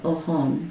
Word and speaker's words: Oljon: It's currently spelled O-L-J-O-N, Ohon. Mm-hmm. But that would have been like a Spanish pronunciation of the indigenous people there.